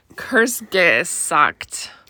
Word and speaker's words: Kurzgesagt: But one of my favorite YouTube channels is this channel called, it's got a lot of consonants and I don't even know if I'm saying it right. Kurtzgegard. – Hold on, I'm trying. Kurtzgesagt. – Yeah.